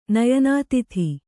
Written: ನಯನಾತಿಥಿ

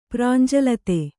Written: ಪ್ರಾಂಜಲತೆ

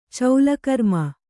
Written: ಚೌಲಕರ್ಮ

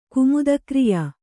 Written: ಕುಮುದಕ್ರಿಯಾ

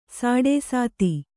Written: ಸಾಡೇಸಾತಿ

